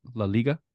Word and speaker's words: La Liga: Did I say that right? La Liga? – Yes.